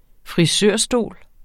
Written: frisørstol

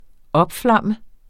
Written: opflamme

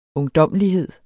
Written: ungdommelighed

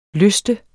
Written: lyste

